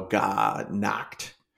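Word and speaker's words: Gha Nachkt: It's God Knocked or something along the, it's two words, G-H-A and then N-A-C-H-K-T. So God Knocked.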